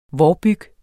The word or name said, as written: vårbyg